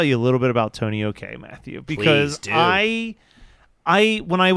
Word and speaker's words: Tonio K: Do you know how this is pronounced? Tony OK? Is that? – I think that's right.